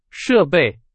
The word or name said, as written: 设备